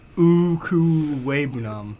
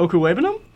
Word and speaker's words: Okwuegbunam: See, Albert Okwuegbunam. – You butchered that. – Okwuegbunam. Look at the screen. I mean, I think that's fair. – Mm-hmm. – Okwuegbunam? – Just right. Stop.